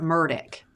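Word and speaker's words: Murdaugh: Um, we were told to pronounce it Murdoch.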